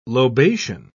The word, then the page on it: lobation: British English